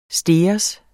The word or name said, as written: stegers